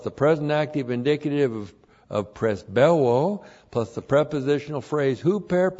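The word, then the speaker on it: presbeuo (πρεσβεύω): And then we have the verb presbeuo. Looks like this in the Greek. Presbeuo, that's P-R-E-S-B-E-U, long O, presbeuo.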